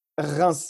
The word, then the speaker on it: Reims: grimes uh, did i pronounce it right grimes or grimes Reims, yeah.